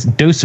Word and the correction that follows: Deus: Vault. – Oh, Deus.